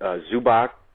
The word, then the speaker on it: Zubac: Is it Zubac? How do you pronounce that? – I think that's right.